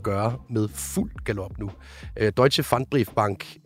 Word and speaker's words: Deutsche Pfandbriefbank: Det hedder Deutsche Pfandbriefbank. Kan du ikke lige prøve at forklare, hvad det er, og måske lige give en bedre udtale af det? – Jo, altså Deutsche Pfandbriefbank er en tysk bank, som er kommet i problemer.